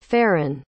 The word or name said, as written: faren